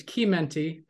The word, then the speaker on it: Chimenti: Technically, it's Chimenti because it's Italian, but I was raised with a Spanish family, so they say Chimenti.